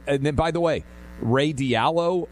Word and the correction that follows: Dalio: and I may be mispronouncing Dalio.